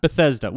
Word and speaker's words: Bethesda: This particular pool then, it's in a town called, how do you pronounce this town? – I say Bethesda. – Bethesda.